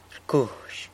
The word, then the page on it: rcuzh: sanlucasquiavini